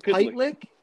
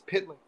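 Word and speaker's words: Pytlík: Am I going to say his name right? – Jaromír Pytlick. – Yeah, Jaromír Pytlick. – It's Pytlick here.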